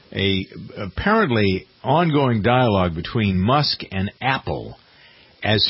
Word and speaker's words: Musk: Yeah, Musk.